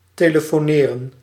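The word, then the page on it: ligar: Portuguese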